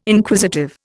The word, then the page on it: Inquisitive: Indian